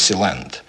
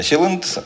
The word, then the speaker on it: Силенд: Кстати, когда я слышал о нем раньше, я э, читал это название с ударением на последний слог. Я думал, что правильно Силенд. Но нет. В общем, правильно Силенд.